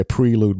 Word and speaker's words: prelude: I like to say prelude because I'm British and that's the correct way to say it.